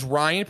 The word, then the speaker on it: Ryan: Is Ryan pronounced wrong? Is there something I'm pronouncing? Like, Jesus, it's Ryan.